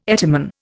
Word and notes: etymon: Indian

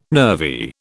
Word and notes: nervy: British English

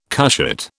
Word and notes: cushat: British English

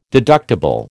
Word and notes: deductible: American English

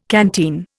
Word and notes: canteen: Indian

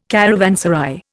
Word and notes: caravanserai: Indian

